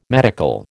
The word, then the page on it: medical: American English